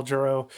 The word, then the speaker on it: Jarreau: The first name is it Al Jarreau? Is that how you say his name? – Yeah, that's that's how I normally Al hear Jarreau, Al Jarreau,